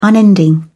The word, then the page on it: unending: British English